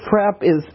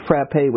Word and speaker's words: frappe: It's like a very, frap is a mispronunciation of frappe, which means beaten, which is really, it's like a very thick malt.